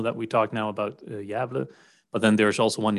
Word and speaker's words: Gävle: So it's in a city perhaps named Gävle. Gävle. – Yeah. That's a very accurate pronunciation. It is a city, and it's called Gävle.